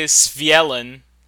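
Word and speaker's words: Svyelun: I would say Svielen. – Oh. – Svielen. Like, it looks a little Norsey.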